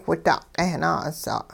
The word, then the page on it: whida'-q'eh-naa'us'a': hupa